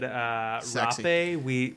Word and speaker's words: rapé: Is that like it's spelled rape, but with an accent on the.